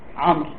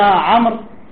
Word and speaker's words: عمرو: فهذا أعجب به وعينه أعلم النحاة إلى آخره، لأن داود تكتب بواو واحدة وتلفظ بمد الواو، أما عمرو فتكتب بالواو لكن لا تلفظ الواو فيها، فقال عمرو سرق الواو من اسم داود.